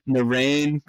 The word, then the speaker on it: Narain: There's only been two: Narain Karthikeyan.